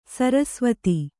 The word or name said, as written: ಸರಸವತಿ